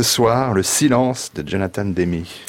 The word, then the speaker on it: Demme: Demme.